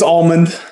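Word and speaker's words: Allmand: It's Almond. – Almond, like the nut. – So it's pronounced like the nut, but it's spelled like the Almond Brothers with a D on the end.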